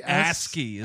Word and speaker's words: ASCII: He's sending her isky art of what his dick looks like. Remember isky art or whatever? – Yeah, am I saying it wrong? I don't even know what this is. – ASCII.